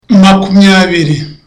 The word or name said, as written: makumyabiri